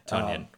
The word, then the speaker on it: Tonyan: Tonyan, remember? We went over this. – That's right, strike two. That's at least the second time I've said that wrong.